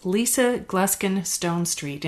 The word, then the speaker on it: Lisa Gluskin Stone Street: My next guest is Lisa Gluskin Stone Street. And did I pronounce that correctly? – Yes, you did.